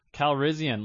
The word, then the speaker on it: Calrissian: Calrissian, Lando Calrissian.